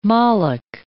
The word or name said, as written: Moloch